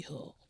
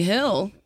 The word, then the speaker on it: Hull: So Tommy Hull. – Mm-hmm. – Tommy Hill? – Tommy, I guess Hill, but it's spelled H-U-L-L. – Oh, Hull. – Like Hull.